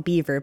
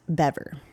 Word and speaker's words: Bever: It looks like it's spelled Beaver, but it's actually pronounced Bever.